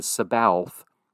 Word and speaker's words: Sabaoth: Sabaoth, it looks like if you're going to write it in English transliteration, you can spell it S-A-B-A-O-T-H. Looks like it says Sabaoth, but it's Sabaoth.